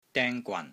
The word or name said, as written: DENG1 GWAN3